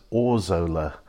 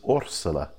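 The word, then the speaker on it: Órzola: Actually, the correct pronunciation is Orsola. Orsola. So there's two things to pick up on there. The first is that there's an accent on the O, which is why the emphasis is on that rather than the Zo. And the second is that the Spanish pronounce Zs as S's. So it's not Orzola, it's Orsola, Orsola.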